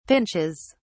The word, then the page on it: pinches: British English